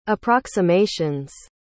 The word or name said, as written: approximations